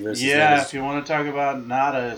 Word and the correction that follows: Nagata: is that how you pronounce it? – No, Joe Nagata. – Nagata, okay, bring it.